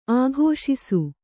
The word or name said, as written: ಆಘೋಷಿಸು